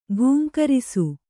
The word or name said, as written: ಘೀಂಕರಿಸು